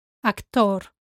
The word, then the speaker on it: actor: Let's start with actor. Actor. Here you have to remember that the stress goes on the O.